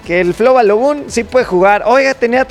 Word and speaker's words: Balogun: Con el tema de Flo Balogun.